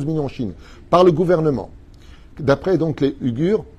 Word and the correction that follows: Ouïghours: comme ça qu'on prononce Ouïghours, autant pour moi, d'accord euh, Basés à Washington.